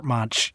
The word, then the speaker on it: Munch: Ooh, thanks for taking that bullet of saying his name before I said Munch while talking about this. – I know. I always pronounced it Munch too. I thought it was like Robert Munch, you know? But no, it's actually Edvard Munch.